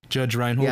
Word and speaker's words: Judge Reinhold: that Judd Reinhold, is that how you say his name? – Judge Reinhold.